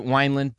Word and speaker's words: Wineland: You're supposed to pronounce it Wineland, but we don't know how you're actually supposed to pronounce it.